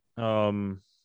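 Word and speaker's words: Okongwu: Okongwa, I said it right for Will this time. He corrected me yesterday.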